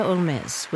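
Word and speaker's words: Holmès: Originally pronounced her last name like Sherlock Holmes, but Frenchified it to Olmez after becoming a citizen of France at the age of 24.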